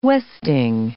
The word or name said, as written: westing